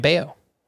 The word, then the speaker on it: Bello: All right, Brayan Bello is making his debut for the Red Sox on Wednesday, and if you're looking to add him, that's spelled B-E-L-L-O, so it's going to look like Bello, but it's actually pronounced Bello,